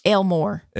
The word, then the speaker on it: Elmore: She started the Wetumpka Tea Party, uh, Elmore County, where I was from. it's where I live. Um, very, you didn't very, say that right. Yeah. Elmore. – Elmore. That's right.